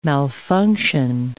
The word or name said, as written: malfunction